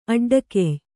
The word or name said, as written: ಅಡ್ಡಗೆಯ್